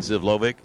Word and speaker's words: Zivlovic: You know, you got to like Peja how do you say that? – Zivlovic.